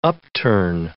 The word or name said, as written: upturn